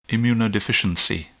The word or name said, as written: immunodeficiency